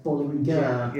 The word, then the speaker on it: Bollinger: Bollinger, you're actually meant to pronounce the G. So it's Bollinger. – Yeah. – Really? – German. It's German.